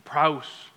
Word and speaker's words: praus: The praus, if you want to phonetically spell it, it's P-R-A-U-S. P-R-A-U-S, the meek, that word praus.